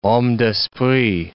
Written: homme d'esprit

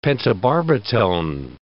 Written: pentobarbitone

